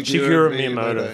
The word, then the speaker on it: Shigeru Miyamoto: Shigeru Miyamoto.